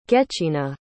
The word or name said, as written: gechina